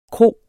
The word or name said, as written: KROERNES